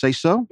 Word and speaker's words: SeSo: It's pronounced SeSo. – Yes. – S-E-S-O, with the capital S's. The two S's are both capitalized.